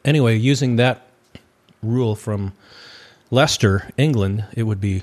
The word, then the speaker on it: Leicester: Yes, because in England, it has to be an English name, because there's a city in England, and you spell it L-E-I-C-E-S-T-E-R. And they pronounce it Leicester. – Leicester, yeah. – So it looks like it should be Leicester, but they just say Leicester.